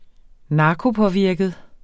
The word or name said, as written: narkopåvirket